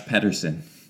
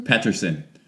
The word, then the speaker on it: Pettersson: How do you botch the pronunciation so badly? – Why? What was it? Is it Pettersson? – Elias Pettersson. – Elias? – Yeah. – Pettersson? – Pettersson. You were close.